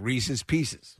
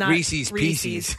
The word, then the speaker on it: Reese's Pieces: Yeah, it's meant to rhyme. Reese's Pieces. – Reese's Pieces. – Not Reese's Pieces.